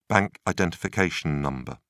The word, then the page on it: bank identification number: British English